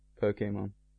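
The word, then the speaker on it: Pokémon: Well, the E has the, what's the word? – Accent. – Accent, of yeah. It, which is pronounced A. Pokemon.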